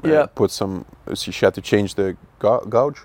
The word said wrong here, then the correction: gauze: Band-aid, gauze, whatever.